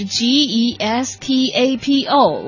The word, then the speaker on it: Gestapo: 在德文里是 G E S T A P O。我们知道德文里头有很多的发音都非常像我们拼音直接拼出来。 – 对。 – 所以我在这里斗胆姑且瞎念一下，要是错了，大家来指正一下。好像是 Gestapo，应该是这样发音吧？ – 对。